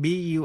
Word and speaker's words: bury: Động từ bury, âm u, trong bury đọc như e, to bury hay là chôn. Burial, b-u-r-i-a-l là lễ an táng.